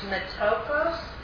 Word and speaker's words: metochos: It's pronounced metokos, M-E-T-O-C-H-O-S.